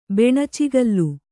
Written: ಬೆಣಚುಗಲ್ಲು

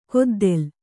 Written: ಕುದ್ದಲ

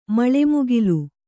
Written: ಮಳೆಮುಗಿಲು

